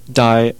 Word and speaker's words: Die: Yeah, but here's the same band, Die, or not Die. D-I-E is pronounced D in Dutch, and I keep pronouncing it Die because it looks like Die.